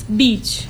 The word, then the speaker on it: beach: So it means like double E ya, kayak double E ya, beach.